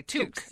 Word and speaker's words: toque: Toque. – Yeah. – I have toque rhymes with juke.